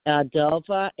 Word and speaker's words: Adelfa: Adelpha, ADELFA.